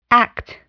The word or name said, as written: act